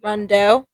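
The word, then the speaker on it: Rondeau: Rondo, Rando, French. – Billy. – France. – Yeah. what what sounds right? Rondeo or Run? Rondo. Okay, so Rondo Provincial Park.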